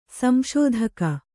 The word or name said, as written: ಸಂಶೋಧಕ